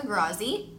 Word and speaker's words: Grazzi: My Instagram is my first and last name, which is Francesca Grazzi.